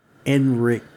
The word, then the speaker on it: Ehrenreich: Alden Ehrenreich. – can you spell it? – Ehrenreich. – Okay. It sounds like Eric Nick.